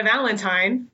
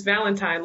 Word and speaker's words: Valentine: my name is Kenna Valentine, just like the day, because my last name is Valentine, like Valentine's Day.